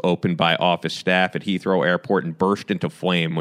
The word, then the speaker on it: Heathrow: Heathrow, yeah.